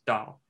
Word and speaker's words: Dao: So the Japanese and Koreans you will use dough in the pronunciation, and the Chinese the pronunciation is Dao, same word has a bunch of different meanings, deeply culturally embedded.